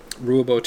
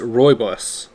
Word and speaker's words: rooibos: Okay, I just looked up how to pronounce the tea. It is rooibos.